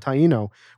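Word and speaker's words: Taíno: I don't know how to pronounce that. – Taino, which the Taino is the Cohiba head.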